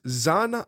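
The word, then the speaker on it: Xan: So her full name is Zan Irelia. And that's X-A-N, Zan Irelia. That is the pronunciation that we are sticking to.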